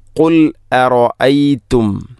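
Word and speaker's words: قُلْ أَرَأَيْتُمْ: 30-ാമത്തെ ആയത്തിൽ ഖുൽ അറഅയ്തും നേരത്തെ പറഞ്ഞതുപോലെ ഖുല്ലറായിത്തുമല്ല ഖുൽ അറഅയ്തും ഇൻ അസ്ബഹ ഇൻ അസ്ബഹ രണ്ടും മാറ്റാണ്.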